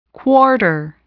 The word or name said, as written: quarter